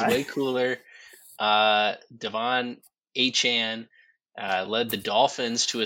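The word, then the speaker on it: Achane: The breakout rookie running back for the Miami Dolphins has informed the team that his name is pronounced Achan. That's right. It's Devon Achan.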